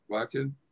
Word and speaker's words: Wacken: You also talked about is it Wacken? Is that how you pronounce it? Wacken? – Yeah.